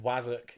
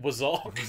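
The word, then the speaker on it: wazzock: You saying Zabaz just reminds me of when we taught you the word Wazzuk. – And you insisted on pronouncing it Wazok. – Wazuka was my other pronunciation.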